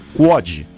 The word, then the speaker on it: QUOD: SPC Brasil e COD, QUOD se escreve Q-U-O-D. Q-U-O-D.